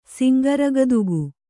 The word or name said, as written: ಸಿಂಗರಗದುಗು